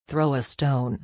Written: throw a stone

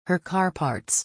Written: her car parts

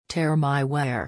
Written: tear my wear

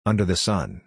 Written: under the sun

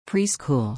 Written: preschool

